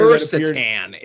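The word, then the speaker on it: Krrsantan: Kirstan is how they say it in the show.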